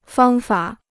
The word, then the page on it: 方法: fāng fǎ